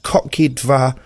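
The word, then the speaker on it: Kotki Dwa: And I went and looked, and if you Google Guardian and Kotki Dva, which is spelt Kotkidwa, and it means, I'm assured, kittens too in Polish.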